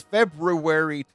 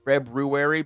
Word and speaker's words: February: This is February 29th. That's right. I pronounced it February because I'm not shortchanging myself on all the letters that are in the month. I say library. I say February.